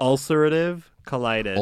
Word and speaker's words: ulcerative colitis: Ulcerative colitis. – What is it? How do you say it? – Ulcerative colitis.